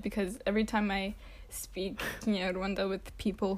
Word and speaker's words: Kinyarwanda: Okay, Kinyarwanda, because that's how you say it. – Say it again. – Kinyarwanda.